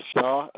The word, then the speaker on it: Shaw: Victor Shaw, S-H-A-W.